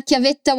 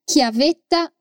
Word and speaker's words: chiavetta: Pendrive em italiano é chiavetta USB. Literalmente significa chavinha USB. É uma palavrinha com dois T's, por isso atenção à pronúncia. Chiavetta. Repete comigo. Chiavetta USB chiavetta USB.